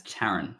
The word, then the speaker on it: Tyron: He does have a bit of potential, and that is none other than Tyron Thomas. Is that how you say it? – You just call him Tyron. – Is it Tyron? – Man, it's Taron. – Taron. – Completely different name.